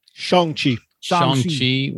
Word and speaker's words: Shang-Chi: Shang-Chi, am I saying that wrong? – Shang-Chi was right for my soul.